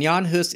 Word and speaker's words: Jan Hus: Yeah, and Jan Hus is the Bohemian way to say his name.